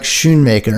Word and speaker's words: Schoonmaker: I botched this name already, but like I do too, because I was talking to Kevin Bolger and he's like Schoonmaker, and I was like, oh, I've been mispronouncing.